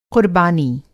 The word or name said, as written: क़ुर्बानी